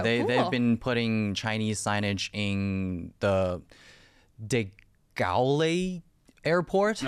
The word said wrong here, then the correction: De Gaulle: No, I'm not sure I'm pronouncing the name right. – De Gaulle, maybe Charles. – Yeah. – De Gaulle. Okay.